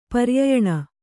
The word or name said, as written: ಪರ್ಯಯಣ